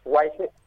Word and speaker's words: Waiheke: Primero, ¿está bien dicho? ¿Cómo se pronuncia? – Waiheke. Sí, sí, perfecto.